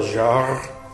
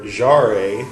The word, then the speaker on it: Jarre: This is Jean-Michel Jarre, or Jarre, as I called it, until my Swedish friend corrected my pronunciation.